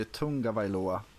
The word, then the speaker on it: Tagovailoa: så uttalar de ju Tungavailoa. – Tungavailoa, så är det faktiskt ja.